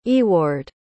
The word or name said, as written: Eward